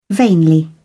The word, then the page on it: vainly: British English